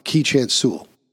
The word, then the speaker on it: Keechant Sewell: But Commissioner Secant, um, am I pronouncing her name correctly? No, Keechant Sewell. Sewell is her last name.